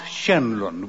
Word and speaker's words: Tserlund: My favourite Swede is Ralph Schenlund, which is spelled T-S-E-R-L-U-N-D. Curious name.